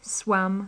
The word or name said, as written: swam